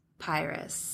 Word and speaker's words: Pyrrhus: But then, how do you say his name? Pyrrhus? – Pyrrhus. – The son? – Yeah, the worst. Yeah, not Paris.